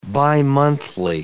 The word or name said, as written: bimonthly